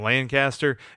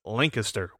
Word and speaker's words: Lancaster: I think I've been saying Lancaster. It's Lancaster, which, funny story about that, by the way.